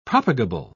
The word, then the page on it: propagable: British English